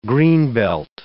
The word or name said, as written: greenbelt